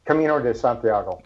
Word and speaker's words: Camino de Santiago: Camino de Santiago, and I hope I'm saying that correctly. – That was Camino de Santiago.